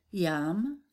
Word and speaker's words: yam: Yam for the heart chakra. Pronounce the word like the word yum, as in yummy.